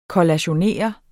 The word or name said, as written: kollationere